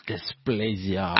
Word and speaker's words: dysplasia: Septo-optic diplegia. dysplasia. – Dysplasia. – Ah, dysplasia.